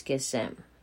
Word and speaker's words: kisem: Kung ating, sabi sa, tra- sa transliteration, kisem. Pag ipopronounce po natin is kisem.